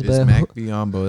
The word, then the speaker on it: Bismack Biyombo: Bismack Biyombo.